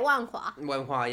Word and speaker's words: Wenhua: Taipei Wenhua, yeah. I think it's Wenhua. That's how it's spelled, right? – 没错.